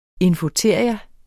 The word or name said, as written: infoteria